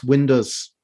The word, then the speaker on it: Windass: Rob Staten pronounced Windass Windows. – Weird. – Yeah, like like Findus crispy pancakes.